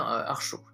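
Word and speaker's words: Archot: Et pour le coup, non, la prononciation, c'est bien Archaud, il me semble. En tout cas, ça s'écrit A-R-C-H-O et un T, il me semble, à la fin.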